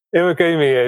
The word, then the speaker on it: Iwagumi: I always put Iguami. What is the actual pronunciation? – Iwagumi is Nature Aquarium.